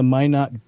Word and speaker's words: Minot: Air Force maintenance and security personnel stationed within the Minuteman Intercontinental Ballistic Missile Complex surrounding the Minot. The Minot, is that, am I saying that right? The Minot Air Force Minot. Base, North Dakota. – Minot.